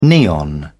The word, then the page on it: neon: British English